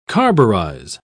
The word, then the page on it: carburize: British English